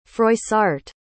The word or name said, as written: froissart